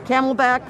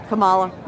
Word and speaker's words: Kamala: I don't think Biden's going to make the 4 years, and the camelback, forget it. – The camelback, who's that? – Kamala. – Oh, wow. That both mispronounced the name and xenophobic.